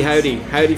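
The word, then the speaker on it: Hovvdy: It's got to be Howdy from Austin, Texas. Their fourth Hov D. they confirmed in an AMA on Reddit the other day that yes, in fact, it is pronounced howdy.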